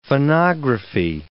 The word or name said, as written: phonography